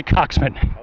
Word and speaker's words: coxswain: I believe it's spelled C-O-X-S-W-A-I-N. – I'll go with that. – Right? Coxman. And I think they say coxman.